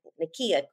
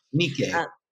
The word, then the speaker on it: Nikkei: She's an award-winning Miami-based chef who, along with her family, opened Itame in the Miami Design District, focusing on Nikkei cuisine. Saying that wrong. – Nikkei. – Nikkei cuisine.